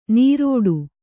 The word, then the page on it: ನೀರೋಡು: nīrōḍu